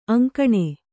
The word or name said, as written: ಅಂಕೋಣಿ